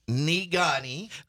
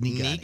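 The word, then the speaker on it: Negaunee: Nugani. – why can't you pronounce words? Nigani. – That's Nigani.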